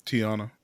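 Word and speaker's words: Teyonah: Tiana? Am I saying that right? – Tiana.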